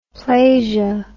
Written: plasia

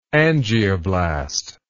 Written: angioblastic

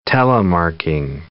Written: telemarking

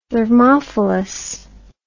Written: thermophyllous

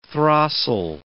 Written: throstle

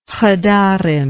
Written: chadarim

